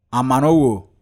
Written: AMANOWO

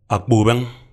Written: Akpobeng

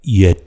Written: Yet

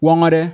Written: Wawngawde

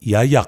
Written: Yayak

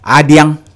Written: Adiang

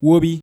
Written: Wobi

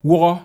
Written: Wuhaw

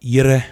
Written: Yire

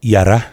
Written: Yara

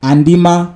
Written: Andima

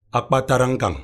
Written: Akpatarankanng

